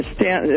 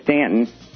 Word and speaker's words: Staunton: The Stanton, as they pronounce it, Staunton, it's spelled. – They do pronounce it Staunton, don't they? – Yeah, it's spelled Staunton, but it's down here we say Stanton.